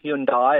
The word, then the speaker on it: Hyundai: It's pronounced Hyundai. It always is. It's dropped the I, so it's not Hyundai. It's not Hyundai. It's Hyundai.